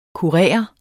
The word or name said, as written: kurer